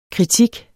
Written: kritik

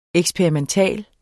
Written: EKSPERIMENTAL

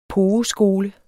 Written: pogeskole

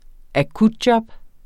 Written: akutjob

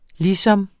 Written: ligesom

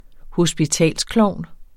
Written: hospitalsklovn

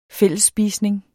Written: fællesspisning

